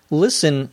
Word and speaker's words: listen: You just have to realize that many words in English have what we may call a silent T. For example, C-A-S-T-L-E is castle, not castle. There are some words where you may hear the T being pronounced when it is said very slowly, but not when it is said in normal conversation. Listen, however, is never one of those cases.